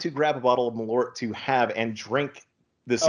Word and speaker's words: Malort: It is Malort. You are pronouncing it 100% correctly. What do some people say? Merlot. It's like, no, it's not a wine.